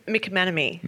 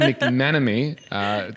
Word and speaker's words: McManamy: And Anna, I don't want to butcher your last name like I did just previously, but how do you say your name properly? – It is McManamy. – McManamy. Anna McManamy. – Close. McManamy.